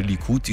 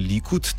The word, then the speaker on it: Likud: Uh, Popravljamo se, izgovori se Likud in ne Likud,